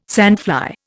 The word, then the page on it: sandfly: Indian